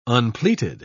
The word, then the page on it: unpleated: British English